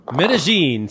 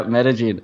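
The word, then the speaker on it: Medellín: Medellin. See, that's how you say it. I was corrected by a Colombian. – Yeah, Medellin. That's the paisa way to say it.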